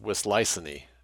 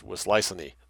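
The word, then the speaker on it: Wisliceny: Now, I'm not sure how this is pronounced. It looks Czech. Wislicene. Okay, I'm looking it up. I believe it's pronounced Wislicene. Wislicene. So more or less as it appears, Wislicene.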